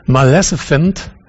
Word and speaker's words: Maleficent: Wie spricht man es richtig aus? Maleficent. Maleficent.